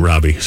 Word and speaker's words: Robby: A lot of people think it's I-E. It's a Y at the end of Robby.